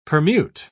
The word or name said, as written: permute